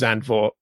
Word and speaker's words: Zandvoort: The one I always go with is Zandvoort because that's how it's spelt and it's the one I hear the most.